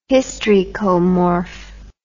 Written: hystricomorph

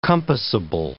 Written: compassable

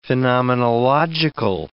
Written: phenomenological

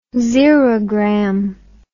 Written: xerogram

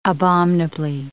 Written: abominably

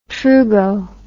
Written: trugo

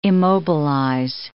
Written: immobilize

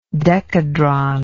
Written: decadron

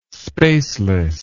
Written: spaceless

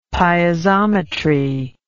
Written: piezometry

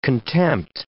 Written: contempt

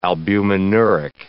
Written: albuminuric

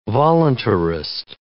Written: voluntarist

